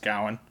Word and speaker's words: Gawain: We went to see The Green Knight, which is David Lowry's take on the classic Arthurian tale of Sir Gawain, pronounced Gowan.